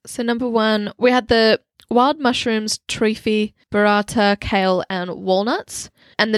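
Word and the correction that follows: trofie: Is it like trophy? – Oh, yes. I realized I've misremembered it. It's trophy, isn't it? – Yeah. T-R-O-F-I-E.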